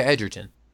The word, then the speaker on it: Edgerton: I'm sorry, is that Edgerton? I can't say his name. Am I close enough? – Yeah, Edgerton. – You were close enough. Yes.